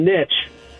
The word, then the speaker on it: niche: the word niche is how I pronounce it. Dom pronounces it niche. You are allegedly a linguistic expert because you love to correct Dom. Where do you stand, sir? – I stand with Dom, niche.